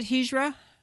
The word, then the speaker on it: Hijra: I mentioned to our listeners that you are the author of Refugee Resettlement, and I want to ask you how to pronounce H-I-J-R-A. Is it Hijra? – Hijra.